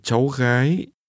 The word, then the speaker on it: Cháu gái: And one daughter hãy nghe và nhắc lại và một cháu gái gái gái cháu gái This is pronounced with two rising tones.